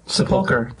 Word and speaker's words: sepulchre: It's seriously not sepulchre? How do you say it? – Sepulchre. – Sepulchre? – Yep.